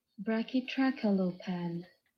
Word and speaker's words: Brachytrachelopan: And today, in Sauropod Month, we are doing the Brachytrachelopan. I think I butchered that. The Brachytrachelopan. And if you don't believe me, this is the pronunciation. Here's a video of the pronunciation. – Brachytrachelopan.